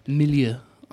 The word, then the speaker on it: milieu: milieu of the day. – Did lovely. I say that right? You did. Lovely use of milieu.